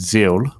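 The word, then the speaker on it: Xeal: I have no affiliation with, but it's called Zeal, which is spelled with an X, X-E-A-L.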